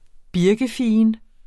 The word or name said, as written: birkefigen